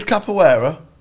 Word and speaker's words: capoeira: Is it the capoeira, which is more like a dance, like sort of martial arts sort of thing, or else?" – I did capoeira.